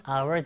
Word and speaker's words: hour: 얘는요, 철자로만 보면 H로 시작하잖아요. 전혀 모음이 아닐 것 같지만 발음이 our 아닙니까? 아, 모음 발음이기 때문에 한 시간은 an hour 되고요.